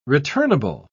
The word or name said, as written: returnable